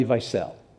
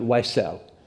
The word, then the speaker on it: Wiesel: How many of you have ever read anything by Elie Wiesel? Or you might pronounce it, you're tempted to pronounce it Wiesel.